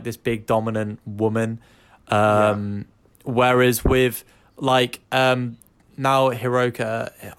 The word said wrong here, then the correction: Hikaru: how do we say her name? – Hikaru. Hiroka, what the fuck? – Piss off.